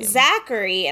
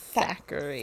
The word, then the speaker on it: Thackery: I always thought it sounded like a person's name. Zachary, and I was like, why is everyone pronouncing like Zachary wrong? – Thackery.